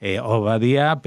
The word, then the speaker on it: Obadiah: Y ese nombre es el nombre que en hebreo se pronuncia Obadiah,